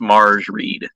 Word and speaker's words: Mairghread: It's spelled Mairghread, so I would guess Marge Reed.